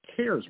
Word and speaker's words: CAERS: The FDA has its own system to track consumer complaints called a CARES website, but it's spelled C-A-E-R-S.